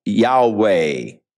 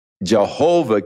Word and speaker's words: Yahweh: So the general consensus of opinion is that the name was to be pronounced Yahweh. But somewhere along the line, the pronunciation of Jehovah came along, or Yehovah, and it has become more popular, but Yahweh is probably the correct pronunciation, though we do not know for sure.